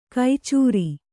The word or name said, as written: ಕೈಚೂರಿ